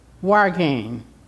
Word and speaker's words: wyrgan: W-Y-R-G-A-N. Wargain.